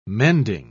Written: mending

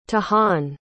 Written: tahan

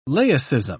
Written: laicism

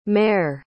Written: mair

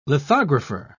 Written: lithographer

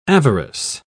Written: avarice

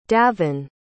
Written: davynn

